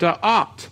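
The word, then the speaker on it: Da'at (דעת): Da'at, it's spelt Dalet Ayin Tav, and it is a covenant term, whereas the other Hebrew word to know is Yada.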